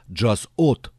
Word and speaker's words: Jazz Hot: Французы произносят «Jazz Hot».